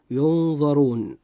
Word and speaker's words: ينظرون: تلاحظ معي أخي المسلم أن حرف الراء جاء في هذه الكلمة مضموما، فيجب أن ينطق مفخما هكذا: ينظرون.